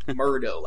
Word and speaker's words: Murdo: Kat Murdo, or Murdo, I always pronounce it wrong. She only corrected me, like, years later. Murdo, like McMurdo.